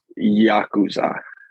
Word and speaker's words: Yakuza: I think it's pronounced Yakuza. – Yakuza. Ah, the okay. Yakuza. Good job. I think I could be so wrong, but I think that's how it is pronounced.